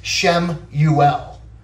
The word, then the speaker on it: Samuel: It's interesting because you look at the name Samuel. Okay, that's how we say it in English, but it really would be pronounced Shemuel, which Shem comes from the word Shema, right?